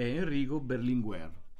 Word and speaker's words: Enrico Berlinguer: Io la prima persona a cui voglio fare riferimento, che è nata proprio oggi, 25 maggio nel 1922, è Enrico Berlinguer, no? Non so se l'ho detto bene. – Detto benissimo. – Okay. Enrico Berlinguer, che è morto purtroppo nell'84 ed è stato il leader del Partito Comunista Italiano, del PC, del vecchio PC.